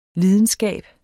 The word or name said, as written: lidenskab